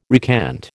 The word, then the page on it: recant: American English